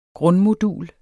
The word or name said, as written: grundmodul